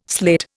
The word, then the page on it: sleight: Indian